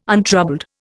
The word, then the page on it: untroubled: Indian